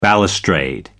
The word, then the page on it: balustrade: American English